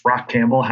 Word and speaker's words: Rachampbell: Stone Soup is just how I how I introduce myself as a way to say my name is Rachampbell, how you pronounce the name.